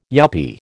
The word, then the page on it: yuppy: American English